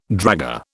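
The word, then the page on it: dragée: British English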